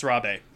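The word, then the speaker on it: Rabé: An accent on the E. Yeah, it's Rabe.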